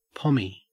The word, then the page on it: pommie: Australian English